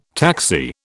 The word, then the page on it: taxi: British English